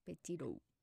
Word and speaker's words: Petito: Entonces, hoy voy a hablar del caso de Gaby Petiro ok. – De ahora en adelante le voy a decir Gaby. – Sí, sí, sí, porque está medio. – Petito. Se escribe petito así, en Spanish.